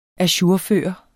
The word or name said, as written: ajourføre